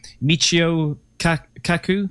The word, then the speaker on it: Michio Kaku: Well, because there's a doctor, forgive me if I've pronounced his name wrong, Dr. Michio Kaku. – Yes.